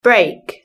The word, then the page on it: break: British English